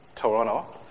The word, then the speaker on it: Toronto: Actually, my first trip to Toronto. I learned how to pronounce it. No T's, no second T's.